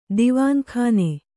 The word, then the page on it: ದಿವಾನಖಾನ: divāna khāna